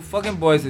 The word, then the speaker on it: FOKNBOIS: Yeah, so Fucking Boys is FOKNBOIS.